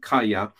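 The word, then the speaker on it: Kaya (חיה): In fact, I didn't go into last week, but that word for live is a Hebrew word spelled H-A-Y-A, but it's pronounced like Kaya.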